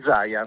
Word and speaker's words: Zaia: Quindi e Zaia si pronuncia esattamente. Sì, giusto? sì, si pronuncia Zaia. – È più Zaya.